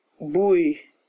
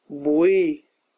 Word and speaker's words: boé: Boé, boué, masculin. On prononce boué.